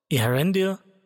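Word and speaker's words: Eärendil: Earendil beginnt nicht mit einem Diphthong, hat am Wortanfang also eine Silbe zu viel.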